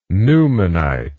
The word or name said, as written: newmanite